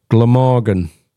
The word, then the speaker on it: Glamorgan: Penarth Pier in the valley of Glamorg. Glamorgan. Fucking hell, I didn't say that right either, did I? Glamorgan. Glamorgan, Wales. Ah, fucking.